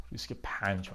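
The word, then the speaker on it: RISC-V: ریسک پنج یا ریسک وی من حتی این نمیدونم درست چی تلفظ میشه چجوری میتونیم بفهمیم که چی درست تلفظ میشه بذاریم ببینم ریسک ریسک 5 ریسک 5 من باید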